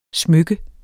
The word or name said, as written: SMYKKERNES